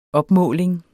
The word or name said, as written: opmåling